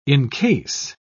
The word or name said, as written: incase